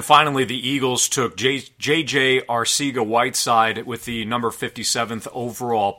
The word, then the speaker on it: Arcega-Whiteside: And I want to correct you before anybody corrects you on Twitter, it's Arcega-Whiteside.